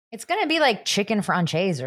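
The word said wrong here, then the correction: Française: No, see, again, the proper pronunciation is chicken franchais.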